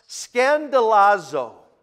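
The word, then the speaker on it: scandalazo (σκανδαλίζω): It comes from a Greek word pronounced scandalazo. Scandalazo. We get our word scandal from it.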